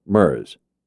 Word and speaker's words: Mers: Those who work with him call him MERS, to rhyme with whirs and stirs.